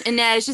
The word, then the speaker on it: Inej: Okay, Inej. Inej is how you say it.